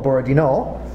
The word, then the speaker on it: Borodino: Borodino. – Mm-hmm. – No, big emphasis on no.